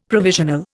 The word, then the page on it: provisional: Indian